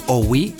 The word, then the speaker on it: Ouie: Se escribe O-U-I-E, Owi.